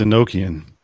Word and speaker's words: Enochian: It's called not Enochian. – It's Enochian.